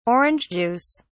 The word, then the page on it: orange juice: British English